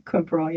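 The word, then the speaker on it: quinpirole: So you have rats, and these rats were given Quinperol. Am I pronouncing that correctly? – Quinperol, yeah.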